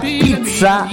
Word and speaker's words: pizza: Recordemos que la palabra pizza italiana incluye dos zetas y se pronuncia las dos, es pizza.